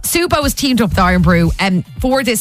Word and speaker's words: Irn-Bru: Is it Iron Brew? – Iron Brew, yeah. – I-R-N-B-R-U.